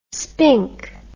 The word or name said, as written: spink